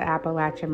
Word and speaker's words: Appalachian: If you are from where I am from, the Midwest, or anywhere else in America, you probably have heard it pronounced Appalachian Mountains, but the people that live there pronounce it the Appalachia, and that is their area. So if they're pronouncing it as Appalachia, I'm going to be respectful and pronounce it the same way.